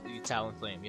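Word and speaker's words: Talonflame: Am I saying that right? Talon Flame. – Talon Flame. Yeah.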